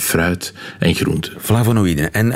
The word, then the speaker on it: flavonoïden: Eh, ja, flavonoïden.